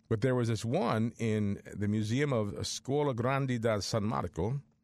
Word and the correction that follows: Scuola Grande di San Marco: I'm sorry, let me pronounce that again. I mispronounced it. Scuola Grande di San Marco in Venice, Venezia, is hosting an exhibit of the Shroud of Turin that began last month.